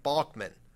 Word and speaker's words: Balkman: It's pronounced with a hard GH, like Balkman.